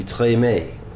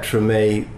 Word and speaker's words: Tremé: On his tomb, there are accents over both E's, the T-R-E-M-E, which would mean in French it would be Tremé. Each E pronounced A. But of course, a lot of people who live here pronounce it Tremé. And then you got Tremé, as we pronounce on the show, which is sort of T-R-U-H-M-A-Y, Tremé.